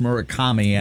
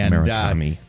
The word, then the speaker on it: Murakami: Yeah, I don't know if his first name is Munetaka, but I believe his last name is pronounced Murakami. – Murakami.